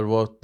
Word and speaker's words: Wirth: Haftanın e, tatsız haberleri, 2024'ün ilk kayıp haberleri, Pascal dilinin yaratıcısı Niklaus Emil Wirth, Wirth diye mi okunuyor? Aslında onun şakasını da yapmışlar. Amerikalılar Word diye okuyormuş, İngilizler Wirth diye okuyormuş.